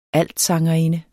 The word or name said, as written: altsangerinde